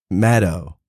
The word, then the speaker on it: Maddow: And he goes, well, she said, actually, it's Maddow. Like shadow.